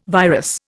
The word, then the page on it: virus: Indian